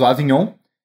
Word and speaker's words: Avignon: Ó, o Collier falou Avignon, exatamente como se escreve da mesma, tipo, finaliza igual o filé mignon, né? Então eu acho que se pronuncia mignon, aqui no caso Avignon.